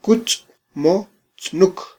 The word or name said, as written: kutzmotznuk